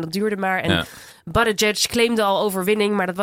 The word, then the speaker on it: Buttigieg: Ja, Buttigieg.